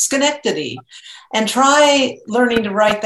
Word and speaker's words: Schenectady: Schenectady. But it's pronounced Schenectady.